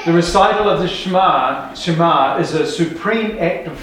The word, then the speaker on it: Shema: S-H-E-M-A.